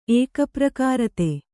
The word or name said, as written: ಏಕಪ್ರಕಾರತೆ